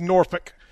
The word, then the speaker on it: Norfolk: I thought it was Norfolk, and guys are saying. – And if you're gonna act like a local, and Aaron, I know you want to, you pronounce it like they do, which is Norfolk.